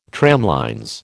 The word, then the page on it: tramlines: American English